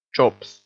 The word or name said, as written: chops